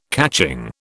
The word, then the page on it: catching: British English